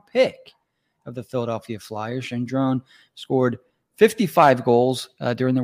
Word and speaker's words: Gendron: In French, they say Gendron.